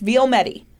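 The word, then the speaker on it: Vielmetti: I would like to give a big shout out to Bruce Vilmedi, obviously. That's how it's pronounced. Vilmedi.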